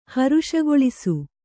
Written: ಹರುಷಗೊಳಿಸು

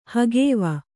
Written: ಹಗವು

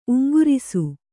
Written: ಉಂಗುರಿಸು

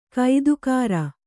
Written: ಗೊತ್ತುಗುರಿ

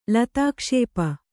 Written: ಲತಾಕ್ಷೇಪ

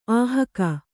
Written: ಅಹಗೆ

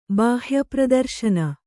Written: ಬಾಹ್ಯಪ್ರದರ್ಶನ

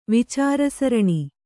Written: ವಿಚಾರಸರಣಿ

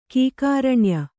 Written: ಕೀಕಾರಣ್ಯ

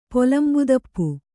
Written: ಪೊಲಂಬುದಪ್ಪು

